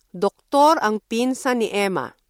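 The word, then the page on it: doktor: Filipino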